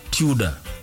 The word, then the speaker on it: Tudor: Inaandikagwa T-U-D-O-R. – Alafu na pronunciwa. – Unasema Tudor. – Tudor. – Tudor. Sio Tudor. – Tudor. Si kuna mali. – Hiyo ni Tudor.